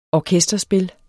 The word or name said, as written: orkesterspil